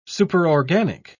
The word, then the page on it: superorganic: British English